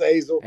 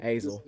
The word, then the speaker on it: Azle: So, I've heard Azel. Like, there's been a bunch of different ones, but it's just Azel. – Azel.